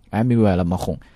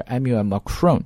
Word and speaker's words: Emmanuel Macron: 啊，这个东西录得很好听 listener friendly 都可以用这个词，所以 business friendly 我们可以把它翻译成叫做对于商业非常友好的，或者叫重视商业的这个新任的总统 Emmanuel Macron，但这是法语的读音，如果我们读英语叫 Emmanuel Macron 啊都可以。